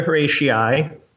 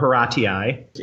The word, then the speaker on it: Horatii: Yeah, I also have Oath of Horatii, spelled T-I-I. I wasn't sure if that was the right pronunciation, but. – The correct answer to that is the Oath of the Horatii, or Horatii. In French, it is Les Sermons des Horaces.